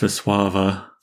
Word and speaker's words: Wisława: We're talking about the poetry of Wisława Szymborska today, and I do believe it's pronounced Wisława. – Really? – Some of those L's are W's, and I think most of the W's are V's.